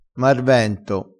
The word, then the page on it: marvento: dialettale